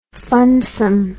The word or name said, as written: funsome